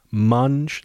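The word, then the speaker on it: Manj: This is an album called Munj. That's M-A-N-J. Munj.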